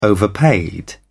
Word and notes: overpaid: British English

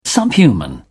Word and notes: subhuman: British English

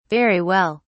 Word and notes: very well: British English

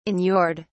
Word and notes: inured: British English

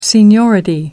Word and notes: seniority: American English

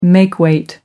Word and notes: makeweight: American English

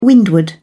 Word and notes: windward: British English

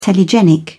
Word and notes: telegenic: British English

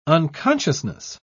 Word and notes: unconsciousness: British English

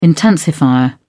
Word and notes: intensifier: British English

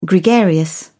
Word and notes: gregarious: British English

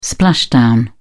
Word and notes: splashdown: British English

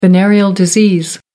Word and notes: venereal disease: American English

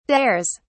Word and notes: dares: British English

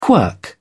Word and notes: quirk: British English

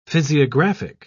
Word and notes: physiographic: British English

